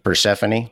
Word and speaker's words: Persephone: They're either born from an affair between Zeus and a human princess, or born between Zeus and his daughter, Persephone. I think that's how you say it. – Persephone.